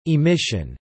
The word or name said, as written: emission